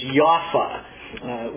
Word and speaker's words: Jaffa: And so Joppa, it's always spelled in your Bible as we usually pronounce it today, but just do be aware that actually in Israel, those P's are pronounced as F's, and the J is not a J, it's a Y. So the way they pronounce it is Jaffa.